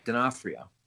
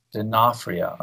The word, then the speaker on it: D'Onofrio: How do you pronounce his last name? – D'Onofrio. – D'Onofrio. Okay. Yeah.